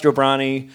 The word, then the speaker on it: Jobrani: And then joining us across the table is Maz Jobrani from, did I say that right? – Yeah, you nailed it.